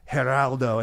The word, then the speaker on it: Geraldo: Yeah, and then Seagal says Geraldo. And like Geraldo. Perfect like Spanish pronunciation.